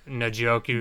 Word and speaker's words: Njoku: So you, uh, Njoku.